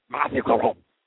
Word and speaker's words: Matthew Caron: What a save by Matthew Caron. You're saying it wrong, Doc. Doc, it's Matthew Caron. It's not French enough.